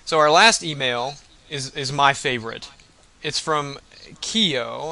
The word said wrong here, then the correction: Keo: No, it has it in parentheses how you're supposed to pronounce it. He was very particular. – Yeah, but I can't... I don't even know that. I can't Kai-O. – Kai-O. Okay, Kai-O. I'm sorry. – It looks like Kai-O.